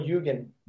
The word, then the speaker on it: Jugend: Did I get that right? – Turbo Jugend. – Jugend. Oh, the J is silent.